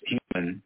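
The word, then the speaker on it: Heumann: But her plegic in childhood, Ms. Hoyman, pronounced human,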